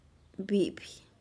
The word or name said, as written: bipih